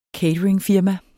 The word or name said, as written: cateringfirma